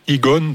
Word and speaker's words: Egaun: Donc tu as choisi Egon, euh, donc ça s'écrit E-G-A-U-N. Euh, ça signifie quoi Eh bien, si on le lit à l'envers, ça fait nuage.